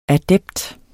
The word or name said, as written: adept